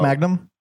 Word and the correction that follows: Mangum: It's uh, it's it's not pronounced like Magna. It's man- Mangum.